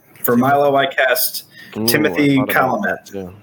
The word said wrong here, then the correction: Chalamet: Chalamet, I'm pretty sure.